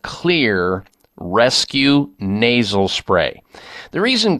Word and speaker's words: Xlear: This is safe, and one of the lead ingredients in it is xylitol, and that's why the name Clear. Clear is spelled X, as in the case of xylitol, X-L-E-A-R, and their rescue formulation is incredible, containing oregano, tea tree, parsley, patiarco, and grapefruit seed extract.